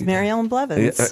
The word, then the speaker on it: Maryellen Blevins: Yes, Maryellen Blevins.